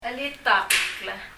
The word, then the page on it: cheritak: [! ɛritʌk]